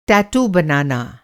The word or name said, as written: टैटू